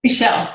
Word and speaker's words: Mychelle: If anybody wants to um, look it up, and then you're going to have to spell your last name, but it's you say it Michelle, right? – Michelle. Yep.